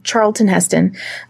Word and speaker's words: Charlton Heston: Charlton Heston.